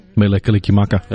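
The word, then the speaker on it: Mele Kalikimaka: Melekalikimaka.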